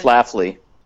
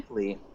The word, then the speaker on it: Schlafly: Schlafly. – Schlafly? – Schlafly, yeah.